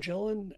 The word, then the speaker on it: Gillan: And, you know, when you get to Karen Gillan, Gillian, how do you say her name? Is that it? Gillan? – Close enough.